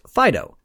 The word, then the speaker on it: FDO: Next was FDO, pronounced Fido.